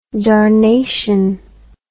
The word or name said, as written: darnation